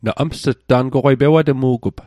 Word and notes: na ms't: Mi'gmaq

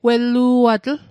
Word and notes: wellu'alatl: Mi'gmaq

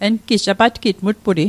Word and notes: apatgitg: apatkitk; Mi'gmaq

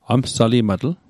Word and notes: amsalimatl: Mi'gmaq

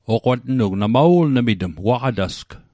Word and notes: waqatasg: Mi'gmaq

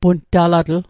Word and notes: puntalatl: Mi'gmaq

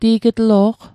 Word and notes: ti'g'tlo'q: Mi'gmaq

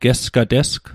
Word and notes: gesgatesg: Mi'gmaq

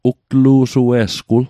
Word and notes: ugtlu'sue'sgwul: Mi'gmaq

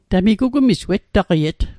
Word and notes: wettaqiet: Mi'gmaq

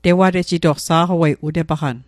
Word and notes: tewatejitoq: Mi'gmaq

